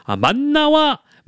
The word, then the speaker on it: 만나: 정답은 바로바로 만나와 메추라기입니다. 만나와 메추라기. 요거 제가 발음 이런 거 잘 볼 거예요. 만나, 미음, 마에 니은 받침이고요. 예, 맞나, 마에 시옷 이런 거 안 됩니다. 오답이에요.